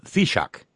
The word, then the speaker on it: Taoiseach: Well, it turns out we got a pronouncer, and that is pronounced Taoiseach.